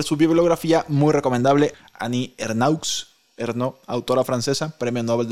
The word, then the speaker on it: Ernaux: Ernaux, de 82 años, que se pronuncia Ernaux,